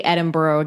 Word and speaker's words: Edinburgh: Edinburgh. I just don't want to say Edinburgh again because I'm going to get corrected. – No, that's the right way to say it.